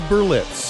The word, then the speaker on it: Berlitz: It's Berlitz.